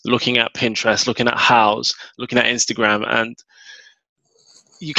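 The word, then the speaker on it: Houzz: So just to clarify the spelling for everyone, it's H-O-U-Z-Z, pronounced mm-hmm. House. Cool. – House, yeah.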